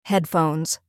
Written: headphones

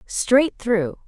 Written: straight through

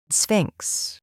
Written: Sphinx

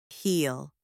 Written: heel

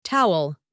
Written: towel

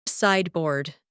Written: sideboard

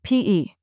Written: PE

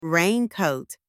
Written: raincoat

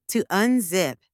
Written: to unzip

